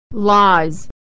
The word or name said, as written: lies